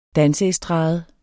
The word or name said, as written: danseestrade